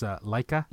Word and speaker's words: Laika: Both, I always say this wrong, it's Laika.